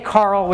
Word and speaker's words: Karel: And some people say Carl, which is kind of actually closer to, I believe, if, I don't know if there's anyone who speaks Czech in the room, but closer to the actual pronunciation. But we say Karel these days because it's kind of like gender neutral.